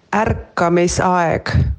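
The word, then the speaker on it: ärkamisaeg: Dit spreek je uit als Arkamisajek. Ik spreek het ongetwijfeld niet goed uit, dus ik heb even een Estse gevraagd hoe je dit uitspreekt. Daar komt hij. – Arkamisajek.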